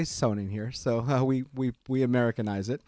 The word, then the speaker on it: Sonnen: And it's actually pronounced Sonnen. – Sonnen. – In German, because we are a German, we're the U.S. subsidiary of a German company.